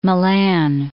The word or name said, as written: Malan